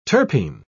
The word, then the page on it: terpene: British English